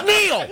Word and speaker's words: Neil: My name's Neil. Four letters. – It's just three, though. They forgot the E. – It's just N-E-L. Isn't that Nell? It's Neil. – No. – It's pronounced Neil. – I actually spelled his name wrong one time. It's N-E-I-L, because if you say N-E-A-L, he'll say...